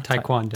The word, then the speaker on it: taekwondo: And did I say that right? Taekwondo? – Taekwondo. – Taekwondo, okay. When I did taekwondo and I got like through a bunch of, you know, white belt stuff.